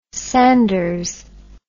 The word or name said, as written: sanders